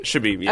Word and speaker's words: Chbeeb: That's how we say it? – Chbeeb? Chbeeb, yeah.